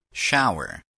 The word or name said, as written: shower